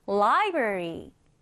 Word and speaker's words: library: 도서관은 library, library라고 말합니다. 자, 뭐라고 한다고요? library. 강세를 맨 앞에 강하게 주시면 돼요. 그래서 library 이렇게 발음을 하시면 됩니다. 자, 우리 오른쪽에 같이 한번 써보겠습니다. library 스펠링은 L-I-B-R-A 그리고 R-Y.